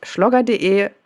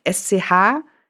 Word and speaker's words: Schlogger: Und meine Sachen kann man sich auch gerne, wer jetzt Blut geleckt hat, einfach auf meiner Website schlogger.de, schreibt man übrigens SCHLOGGER, ansehen oder auf Social Media unter The Schlogger auf Twitter bzw.